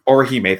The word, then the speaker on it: Orihime: Orime? Orihime, thank you.